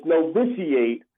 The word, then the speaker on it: Novitiate: We struggle with the pronunciation of it, but it's Novitiate.